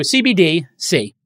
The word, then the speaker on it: CBDC: So CBDC is how I'm going to pronounce it. CBD, like the miracle drug in cannabis, with a C at the end.